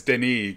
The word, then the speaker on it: Denis: And it's Denis because he's French.